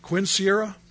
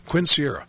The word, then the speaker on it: quinceañera: Quinceañera. – Is that how you say it? Quinceañera.